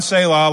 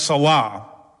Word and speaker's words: Selah: There were three proposed pronunciations of this word. One, Selah, like I've been saying. Another one is Selah. And another one is Salah.